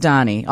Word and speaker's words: Dhani: I know it's spelled D-H-A-N-I, but I've heard a lot of people refer to him as Danny. I wanted to say Donnie. – I think it's Danny, though, right? – Is Donnie.